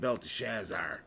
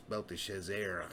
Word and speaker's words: Belteshazzar: Then Daniel, whose name was Belteshazzar, I always said Belteshazzar. I looked at how they pronounce it. It's Belteshazzar. So we'll just stick with the...